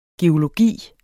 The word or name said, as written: geologi